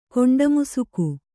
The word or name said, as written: ಕೊಂಡಮುಸುಕು